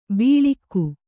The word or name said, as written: ಬೀಳಿಕ್ಕು